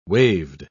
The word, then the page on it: waved: British English